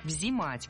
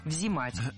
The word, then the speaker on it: взимать: Здесь с ударениями было все в порядке, но меня смутило слово «взимать», потому что так говорить не рекомендуется. Правильно, «взимать». С гласной «и» в первом слоге мы и пишем, и произносим это слово «взимать».